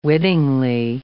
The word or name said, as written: wittingly